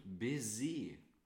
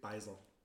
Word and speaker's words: Baiser: Coffee Biscuits und Baiser, wie wir das als, äh, wie wir das als Franzosen so schön aussprechen. – Oder wir hier aus, aus Aschersleben, Beiser.